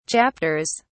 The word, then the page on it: chapters: British English